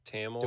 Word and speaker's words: Thamel: Is his name Thamel? Thamel? Tamel? – Tamel. – Do we know how to pronounce that? – I don't know if the H.